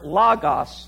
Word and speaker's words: logos: L-O-G-O-S is what? Logos. Actually, in the Greek language, you would pronounce it logos.